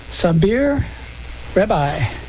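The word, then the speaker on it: Saber Rebaï: That was Sabir Rabbi. Did I pronounce that correctly? – I believe so.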